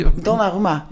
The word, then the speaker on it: Donnarumma: Ja, Donnarumma.